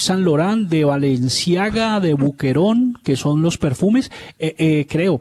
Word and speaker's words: Boucheron: Sí, Boucheron, sí. Pues, como el Boquerón, acá, no, en el... Martín, no así, hombre, eso. Boucheron. – Boucheron. – Suena en francés. Boucheron.